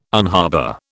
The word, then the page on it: unharbour: British English